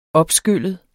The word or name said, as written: opskyllet